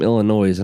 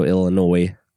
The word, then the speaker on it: Illinois: The owner, super nice lady, she said she was introducing me to some of the people there, and she said he's from Illinois, and I was like, I'm sorry, I don't mean to be that guy, but the S is silent at the end.